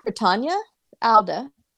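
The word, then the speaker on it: Rutanya Alda: I think Ritanya Alda is how you say it.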